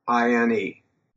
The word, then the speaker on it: Amerine: My last name is spelled Amerine.